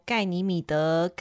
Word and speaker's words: 盖尼米德: 盖尼米德，盖尼米德，盖尼米德。